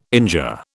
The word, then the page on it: injure: British English